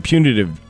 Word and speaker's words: punitive: Damages and $5 million in punitive. Puny damages. – Punitive. – No. – I said it wrong the first time. – Punitive. – Yeah, there you go.